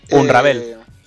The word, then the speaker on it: Unravel: Unravel.